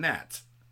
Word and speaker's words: gnats: gnats? And you're like, the gnats! So annoying! What's with the silent G? What were you thinking? There's a silent G in gnats.